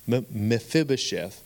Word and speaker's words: Mephibosheth: And I was reminded of the story of, I mispronounce this name all the time, but it's Mephibosheth.